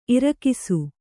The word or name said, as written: ಇರಿಕಿಸು